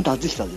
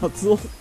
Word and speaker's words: 達久: 鈴木達久っていつもタツオって読んじゃうんですか。達久です。 – タツオ、タツオって読んじゃってあれって思う。